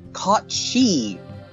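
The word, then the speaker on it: Cat Sìth: Then we go a little bit south to the UK and Celtic lore, and we've got the Cat Sith, or as it's pronounced, Cot She, with the S-I-D-H-E, like with the banshee, you know, type thing there.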